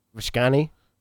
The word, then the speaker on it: Visconti: Visconti.